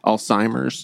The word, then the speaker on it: Alzheimer's: Did I say that right? I always get a little, is it Alzheimer's? – Yeah. – Alzheimer's? – Yeah. There's no T in it.